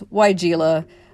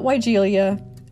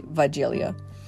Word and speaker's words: Weigela: and the consensus was Weigela, not Weigelia, and of course the original German pronunciation would be Weigelia.